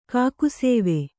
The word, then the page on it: ಕಾಕುಸೇವೆ: kākusēve